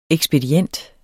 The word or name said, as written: ekspedient